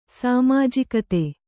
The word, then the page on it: ಸಾಮಾಜಿಕತೆ: sāmājikate